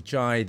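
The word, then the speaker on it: Jy: Once again, thank you to my buddy Jai.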